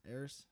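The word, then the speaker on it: ERYS: how do you say it? – Eris. – Which is backwards from Sire, which is basically...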